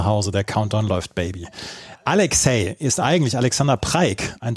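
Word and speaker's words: Aleks-Cee: Und er hatte erst den Künstlernamen Alex C, also Alex A-L-E-K-S, Bindestrich C-E-E, aber das wurde immer als Alexei ausgesprochen, deswegen hat er sich umbenannt in Alexei.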